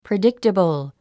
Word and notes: predictable: pridíktəbl; プリディクテボォ

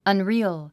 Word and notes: unreal: ʌnrí(ː)əl; アンリアル

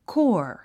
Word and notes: corps: kɔ́ːr; コーォア

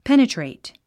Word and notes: penetrate: pénətrèit; ペネトレイト

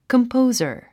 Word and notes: composer: kəmpóuzə r; コンポウザァ

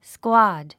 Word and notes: squad: skwάd; スクワード

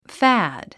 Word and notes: fad: fǽd; ファード

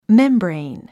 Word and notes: membrane: mémbrein; メンブレィン

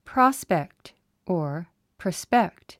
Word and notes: prospect: prάspekt; プラスペクト

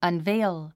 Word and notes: unveil: ʌnvéil; アンベイル

